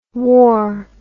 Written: waur

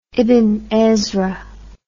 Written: ibn ezra

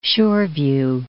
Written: Shoreview

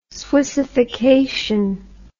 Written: swissification

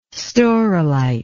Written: staurolite

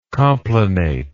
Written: complanation

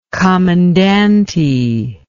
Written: comandante

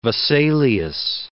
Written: Vesalius

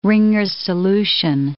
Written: Ringer's solution

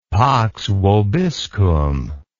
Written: pax vobiscum

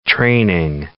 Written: training

